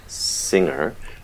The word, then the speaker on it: singer: So words that have the ending tacked on, like singing or singer, lack the hard g.